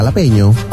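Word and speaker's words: jalapeño: Iya, jalapeno. – Kok tulisannya kan jala? – Kan kalau orang Meksiko, orang Latin ngomongnya gitu. J jadi H.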